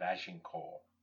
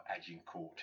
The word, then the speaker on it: Agincourt: This is from the play King Henry V, and the words of King Henry himself, just before the Battle of Agincourt, or Agincourt, if you want to give it the vernacular English pronunciation.